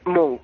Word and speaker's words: Munch: Se dice Munch. – Munch. – Como si fuera una K.